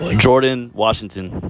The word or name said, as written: JORDAN WASHINGTON